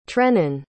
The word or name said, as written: trennen